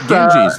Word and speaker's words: Ganges: Ganges, the River Ganges, right? – Yeah, the river, yeah.